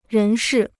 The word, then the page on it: 人事: rén shì